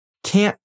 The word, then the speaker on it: can't: Then she continues talking and says, can't talk right now. Let's talk about the word can't first. You've probably noticed that you cannot rely on hearing a good T sound at the end.